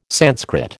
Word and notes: Sanskrit: American English

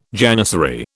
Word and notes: janissary: British English

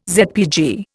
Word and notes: ZPG: Indian

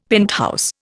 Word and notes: penthouse: Indian

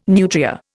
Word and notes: nutria: Indian

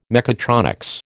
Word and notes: mechatronics: American English

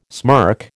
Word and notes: smirk: American English

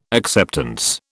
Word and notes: acceptance: British English

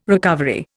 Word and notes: recovery: Indian

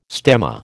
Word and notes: stemma: American English

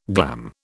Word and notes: glam: British English

